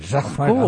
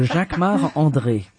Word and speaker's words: Jacquemart-André: Jacques Arnaud, you say it like André. A Frenchman. – Jacques Marc André. Oh, it's because I'm French. So Jacques Marc André.